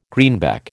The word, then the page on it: greenback: American English